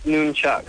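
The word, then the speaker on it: nunchaku: Is it noom, N-O-O-M? – No, N. – It's N-U-N-C-H-A-U-K-U. – Okay, right, but I guess if it's Japanese or something, it'd be noon. – Yeah, it's pronounced nunchuck. – God, what am I doing? – Not... N-U-N, Chuck.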